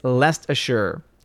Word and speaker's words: Leicestershire: Says it's Leicestershire.